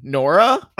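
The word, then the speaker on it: Noura: Up here next we have the other person who I thought could be the first boot preseason, Nora. – It's just pronounced like there was no U.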